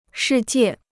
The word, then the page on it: 世界: shì jiè